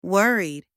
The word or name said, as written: worried